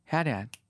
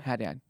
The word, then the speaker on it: Haddad: Haddad. I said the, like, Lebanese way in order to be like, it changed.